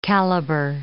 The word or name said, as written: caliber